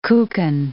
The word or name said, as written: kuchen